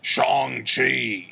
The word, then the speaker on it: Shang-Chi: I've been saying it wrong, and until the fucking, uh, you can definitely tell they're very much worried about people pronouncing wrong because yeah, all the new trailers go out of their way to be like Shang Chi in theaters.